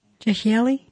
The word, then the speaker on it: Jehieli: Is it the vowel I pronounced E or I typically? – Jehieli. – Jehieli? Okay, that's kind of, yeah. – If you think of that I-E as a Y, I, ye.